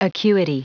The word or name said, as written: acuity